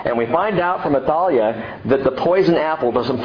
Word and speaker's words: Athaliah: Her name is Athaliah. – That's how you say it.